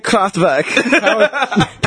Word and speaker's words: Kraftwerk: Kraftwerk, German pronunciation, Kraftwerk.